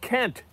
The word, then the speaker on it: Kent: So promo code Kent, K-E-N-T, only way to spell it.